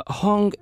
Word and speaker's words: Hong: Hong is H-O-N-G and Jiang is spelled as J-I-A-N-G.